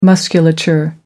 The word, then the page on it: musculature: American English